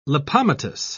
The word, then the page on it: lipomatous: British English